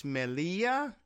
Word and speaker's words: Melilla: But Ceuta and I guess it's Melilla. It's M-E-L-I-L-L-A. And you know how the Spanish pronunciation of the double L's.